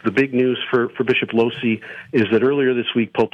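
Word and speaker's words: Lohse: to Bishop Lose. Is that how you say his name? What happened with him? – Losey. Bishop Edward Losey.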